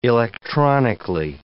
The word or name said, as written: electronically